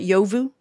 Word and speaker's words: Yovu: Yovu? – Yeah, it is.